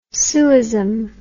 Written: sueism

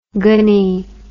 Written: gony

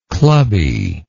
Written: clubbily

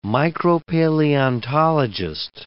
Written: micropaleontologist

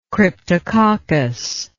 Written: cryptococcal

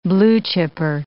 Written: blue-chipper

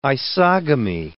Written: isogamy

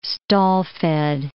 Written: stall-fed

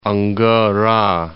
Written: Angara

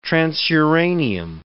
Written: transuranium

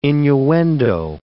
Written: innuendo

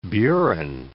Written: burin

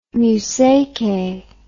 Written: museque

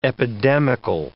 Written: epidemical

